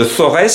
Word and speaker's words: Forez: Alors votre association porte le nom de Forez ou de Forêt si on prononce bien, c'est vers Saint-Étienne.